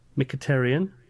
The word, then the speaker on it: Mkhitaryan: So I dropped De Bruyne and I brought in Mkhitaryan. Is that how you say it? – That is how you say it, yeah.